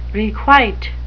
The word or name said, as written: requite